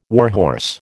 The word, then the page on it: warhorse: American English